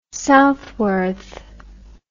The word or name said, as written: Southworth